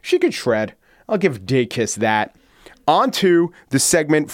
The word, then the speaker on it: Dacus: I do have to correct my pronunciation of Lucy Dacus, who I pronounced, I think, Dacus. I was contacted by a Lucy Dacus superfan.